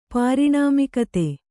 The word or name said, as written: ಪಾರಿಣಾಮಿಕತೆ